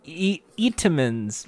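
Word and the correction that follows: Entenmann's: That's Entenmans.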